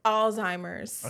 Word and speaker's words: Alzheimer's: Alzheimer's. – Okay, cool. The second time in my life, I done heard the word pronounced right, and she said it both times.